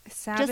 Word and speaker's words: Sabbath: How do you say it again? Sabbath? – Sabbath? – Just like the Sabbath? – Yeah, like Sunday.